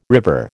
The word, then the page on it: river: American English